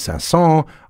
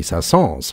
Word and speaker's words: Saint-Saëns: When I'm talking about the French composer Camille Saint-Saëns, I often hear it pronounced without an S, and I hear it sometimes pronounced with an S at the end, Camille Saint-Saëns.